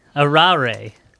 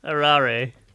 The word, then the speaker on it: Arare: Arare. – Are you okay? – No, it has a pronunciation of the part that I ripped off. – Arare. – Arare. It's not a rare.